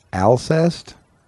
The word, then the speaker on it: Alcest: He's listening to, well, I'm just going to spell it, A-L-C-E-S-T, Alcest. It's like incest, but it's Alcest.